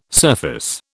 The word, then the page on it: surface: British English